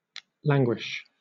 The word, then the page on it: languish: British English